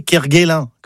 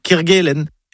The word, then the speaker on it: Kerguelen: Kerguelen. On connaît tous les îles Kerguelen. Alors, pourquoi on entend parfois parler de Ké Kerguelen, comme à Quimper, par exemple? Non, c'est Ké Kerguelen.